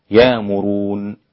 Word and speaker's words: يأمرون: والإبدال يكون نطقه هكذا يامرون يامرون، والباقون بتحقيق الهمزة يأمرون هكذا، ويأمرون الناس بالبخل ويكتمون ما آتاهم الله.